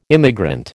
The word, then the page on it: immigrant: American English